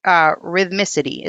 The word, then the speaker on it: rhythmicity: The next one is predictability of physical functions, rhythmicity. Is that how you pronounce it? – Yeah.